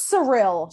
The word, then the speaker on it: Cyril: By the way, is it Zyril? Cyril? – I think it's Cyril. – Cyril? – That's how his mom pronounces it.